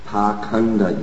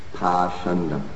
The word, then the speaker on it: pakhanda: The word pakhanda used in this verse, text 12, is sometimes pronounced pashanda.